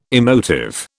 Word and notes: emotive: British English

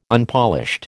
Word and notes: Unpolished: American English

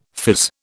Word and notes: fizz: British English

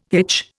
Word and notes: hitch: Indian

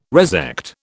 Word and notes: resect: British English

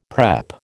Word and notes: prep: American English

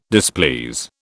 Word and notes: displease: British English

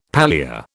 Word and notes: pallia: British English

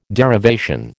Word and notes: derivation: American English

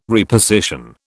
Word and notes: reposition: British English

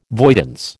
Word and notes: voidance: American English

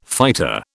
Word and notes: fighter: British English

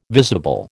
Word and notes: visible: American English

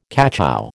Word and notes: cachou: American English

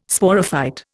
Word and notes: Sporophyte: Indian

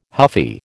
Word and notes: huffy: American English

